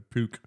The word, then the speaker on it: Puch: Or a puke. – Harry Everett's won a world P-U-C-H.